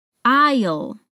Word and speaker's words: aisle: That last word, aisle, is pronounced in a pretty simple way, but the spelling is quite different.